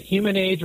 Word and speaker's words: Humanage: That's, it's, a, it's a good question because you know, phonetically it reads human age. It's actually pronounced humanage HR, so just like manage. word human right. and manage all in one word.